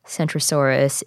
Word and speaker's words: Centrosaurus: Centrosaurus is with a C, and Kentrosaurus is with a K, and also, as you can tell, they're pronounced differently.